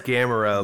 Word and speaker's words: Gamera: It's Gamera. Like the old school Godzilla like turtle monster.